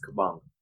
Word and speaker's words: Kabongo: It's Kabongo. – That's good. It's Kabongo? – Like Bongo. You know, like Bongo.